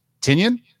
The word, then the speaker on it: Tynion: This is, and I think somebody told us we pronounce his name wrong. It's James Tynion. We added an extra syllable on it, but people love telling us when you say things.